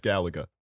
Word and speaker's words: Gallagher: Gallagher. – No, he's just evil. – I thought you just mispronounced his name the second time. – I'm glad that it's that. – It's Gallagher. Don't worry.